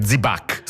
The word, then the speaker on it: Zibac: Si scrive Zwieback, scritto con la V doppia e tutto il resto, ma si sente spesso dire Zibac, scritto proprio così anche. – Zibac. – Molti lo scrivono così, Zibac. Z-I-B-A con l'accento C. – Esatto, la pronuncia si semplifica, si ticinesizza, perde un po' di spigoli tedeschi e diventa Zibac, come hai detto tu.